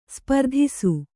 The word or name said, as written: ಸ್ಪರ್ಧಿಸು